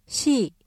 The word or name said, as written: 시